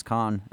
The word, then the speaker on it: Khan: My name is Khan. And when people ask how to pronounce it, I say, like, Chaka Khan.